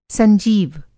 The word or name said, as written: संजीव